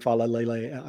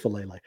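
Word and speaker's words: Faalele: And particularly if they think about, kind of continuing to train Fa'alele. I keep saying it wrong. It's just Fa'alele.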